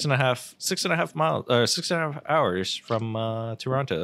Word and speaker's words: Toronto: It's Toronto. You don't pronounce the T.